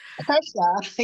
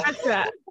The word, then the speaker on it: Kirsha: Kersha, yeah. Kersha.